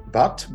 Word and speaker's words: Bhatt: My name is Mukesh Bhatt, B-H-A-T-T.